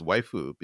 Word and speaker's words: waifu: But like as any weeb can tell you, that is the close to the correct pronunciation for wife in Japanese. It's a romanization of the, you know, English word wife, and it's waifu because they don't have just an F sound as a separate syllable.